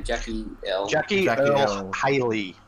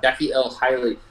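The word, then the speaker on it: Jackie Earle Haley: Jackie Earl Haley. – Yeah. – Jackie Earl Haley.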